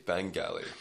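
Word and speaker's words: Bangalley: It's a hard G, Bang Alley.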